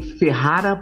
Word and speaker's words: Ferrara: É um fotógrafo gaúcho que está morando em Ferrara, pronunciei certo? Isso aí.